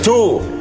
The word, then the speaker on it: two: El dos en inglés es two. – ¿El dos en inglés soy yo? No, señor. Yo no. – El dos en inglés se pronuncia, se dice así. Two.